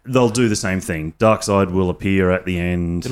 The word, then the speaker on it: Darkseid: Well, it's spelt seed, but it's actually Darkseid.